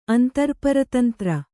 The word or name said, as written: ಅಂತರ್ಪರತಂತ್ರ